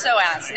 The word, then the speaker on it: SOAS: It's SOAS, named after the muscle in your core. – Ah, okay. – We just dropped the P.